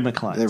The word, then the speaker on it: McLean: But he did tell me in an email, because I never know how to pronounce it, it's like John McLean. – Ah. – Brad McLean.